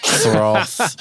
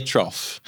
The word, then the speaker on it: trough: Yeah, and it's, I've got to say, this is the one of the things that patients always mispronounce. They call it the tear through, the tear trough. – Yeah. – They can't say trough. – Right. – So it's the tear trough.